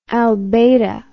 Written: albata

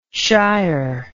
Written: shier

